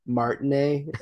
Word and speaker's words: Martinet: I keep calling him Martinet. – I did too until I heard him say Martinet. And then I'm like, okay, that's how you say it.